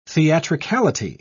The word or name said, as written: theatricality